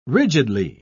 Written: rigidly

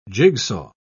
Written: jigsaw

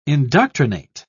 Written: indoctrinate

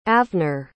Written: avner

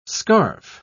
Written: scarf

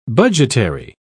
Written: budgetary

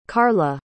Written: karla

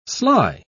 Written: sly

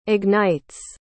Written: ignites

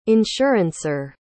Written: insurancer

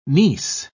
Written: niece

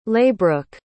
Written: Laibrook

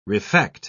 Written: refect

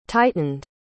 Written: tightened

